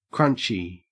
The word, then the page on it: crunchy: Australian English